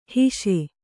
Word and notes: ಹಾಸು: Kannada

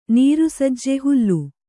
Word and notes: ನೀರುಸಜ್ಜೆ ಹುಲ್ಲು: Kannada

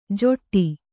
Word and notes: ಜೊಟ್ಟಿ: joṭṭi

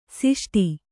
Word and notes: ಷಷ್ಠ: ṣaṣṭha

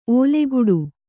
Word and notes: ಓಲೆಗುಡು: ōleguḍu

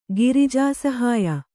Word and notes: ಗಿರಿಜಾಸಹಾಯ: Kannada

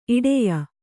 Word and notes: ಇಡೆಯ: kannada

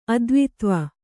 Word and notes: ಅದ್ವಿತ್ವ: kannada

